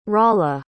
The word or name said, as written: rolla